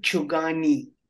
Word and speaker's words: Chugani: So, my name is Shankar Chugani.